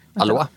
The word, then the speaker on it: Alois: Och nu blir jag osäker på uttalet här, för det är Alois eller... – Alois.